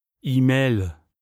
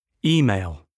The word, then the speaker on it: email: Email. Prononciation à la française. – Email. – Prononciation correcte. – Email.